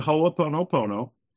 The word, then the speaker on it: Ho'oponopono: And as you know, it's called Ho'oponopono, which I couldn't even pronounce the first time I heard it, let alone try to spell it, let alone understand it. But it's called Ho'oponopono, and it's a Hawaiian word that means to make right.